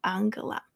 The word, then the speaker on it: Angela: 虽然她 first name 拼法是英文的 Angela，但普遍大家会沿用德文发音念 Angela。她是现任的 Chancellor of Germany，德国总理。有一个很有趣的背景，从政之前她是一名科学家，如同 The Harvard Gazette 文章标题 Angela Merkel，the scientist who became a world leader，成为世界领袖的科学家。